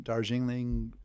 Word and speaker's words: Darjeeling: Like we've had the Darjeeling, is that how you say it? – Darjeeling.